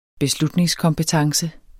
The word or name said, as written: beslutningskompetence